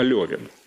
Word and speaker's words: Левин: Некоторые говорят, что его нужно произносить не Левин, а Левин. Лев Толстой, да, значит, от Лёва, Льва, там много очень автобиографичного, действительно много автобиографичного.